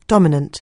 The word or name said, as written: dominant